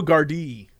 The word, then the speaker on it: Gardaí: It said Los Angeles based podcast show had a bit of bother getting to grips with the concept of Sligo Gardaí after they were discussing an Ocean FM news story. – Well, apparently it's Sligo Gardaí. – Sligo Gardaí. It's even better.